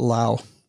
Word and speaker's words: Laos: When I was in Southeast Asia, they were pronouncing it Lao instead of Laos.